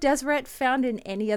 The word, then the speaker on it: Deseret: It's D-S-R-T, but it would have been pronounced something along the lines of Deseret.